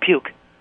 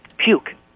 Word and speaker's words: Peuchk: Puke. – Puke, like P-U-K-E? – No. – Okay. Well, how do you spell it? – P-E-U. – P-E-U. – C-H-K. – Hold on. P-E-U-C-H-K. Okay.